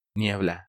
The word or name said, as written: Niebla